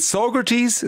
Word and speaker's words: Saugerties: And you're in, is it Saugerties? Is that how you pronounce it? – Yes. – Saugerties, New York.